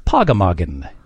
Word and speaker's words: pogamoggan: It's pronounced, I'm sorry, it's spelled P-O-G-A-M-O-G-G-A-N. Pogemoggin.